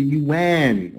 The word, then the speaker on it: yuan: Ah, I'm sorry, it's the yuan, okay.